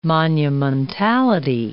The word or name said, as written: monumentality